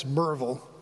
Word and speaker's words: Maryville: But my dad was from East Tennessee, Maryville. Well, that's how it's spelled, but in the local dialect, it's Merville.